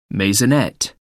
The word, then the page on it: maisonette: American English